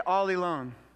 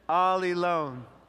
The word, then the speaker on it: allelon: It's the Greek word allelon. And that's how you say it, allelon. Everybody say it, allelon.